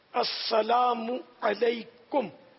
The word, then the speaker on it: السلام علیکم: السلام علیکم یہ تلفظ اس کا,